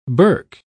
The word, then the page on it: burke: British English